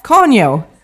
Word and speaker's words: Kanye: Kanye! – Nej! – Men alltså, ni kan inte skratta så här nu. Vi måste se. Det är som att jag skulle stavas säga... K-A-N-Y-E.